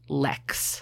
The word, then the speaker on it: lek: So rather than court each female individually, male sage grouses gather in very specific traditional courtship areas called leks. Have you ever heard of a lek? – No. – It's spelled L-E-K.